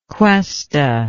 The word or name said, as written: cuesta